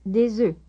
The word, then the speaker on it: des œufs: Notice the difference in pronunciation between one egg and eggs. – Un œuf, des œufs.